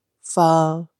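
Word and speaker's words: phở: And once that you get a little side hook on the O, then that is pronounced pho. – Mm. – But then once that you have a little question mark above the O, it becomes pho.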